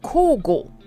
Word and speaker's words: 交互: 呃，我举个例子，交换的交，交呢日文念 call，然后呢，互相的互念 go，所以两个加起来 call go，哦，交互 call go 就是轮流的意思。